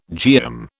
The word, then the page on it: geum: British English